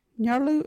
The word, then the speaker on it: Nyarlu: You can use Nyarlu in their sentence, "Are you that Nyarlu talking?" Or "Some Nyarlu at the door." Nyarlu is spelt N-Y-A-R-L-U.